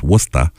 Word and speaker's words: Worcester: W-O-R-C-E-S-T-E-R, by the way, is the spelling of Worcester, which is pronounced Wusta around here, or Wusta, or I've heard the variations.